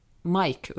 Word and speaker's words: MIC: Men det är intressant det här att det liksom, tydligen finns det ett annat medium som heter eh, MIC, M Mike uttalas förresten, det står för Microphone, MIC, som drivs av millennials, alltså folk som är födda kring 1980.